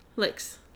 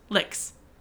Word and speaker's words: Lyx: How would you pronounce L-Y-X? – Lix. – Lix.